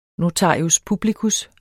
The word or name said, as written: NOTARIUS